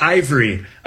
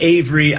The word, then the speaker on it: AIVRE: It's actually Ivory. – Oh, okay. Of course it is. – It's funny because. We were when the company was getting created, we weren't sure how people would pronounce it. So it's like Avery, Ivory, but it's AIVRE, which stands for AI Valuation of Real Estate.